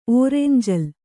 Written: ಓರೆಂಜಲ್

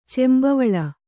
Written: ಚೆಂಬವಳ

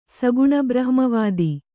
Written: ಸಗುಣಬ್ರಹ್ಮವಾದಿ

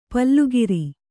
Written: ಪಲುಗಿರಿ

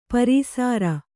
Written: ಪೇರುಸಿರು

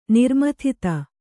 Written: ನಿರ್ಮಥಿತ

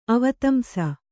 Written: ಅವದಂಶ

